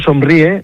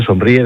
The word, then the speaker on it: Somríe: Pero el grupo Somríe, tal y como suena, Somríe, de sonreír, pero con M.